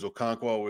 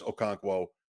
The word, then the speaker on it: Okonkwo: Okonkwo is Okonkwo. That's how you say it, by the way.